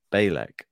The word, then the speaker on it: Bejlek: Belcek to beat Rakhimova. – Well, I've been listening to this one to work out the pronunciation. I looked up yesterday. It's Bejlek. That's how you say it.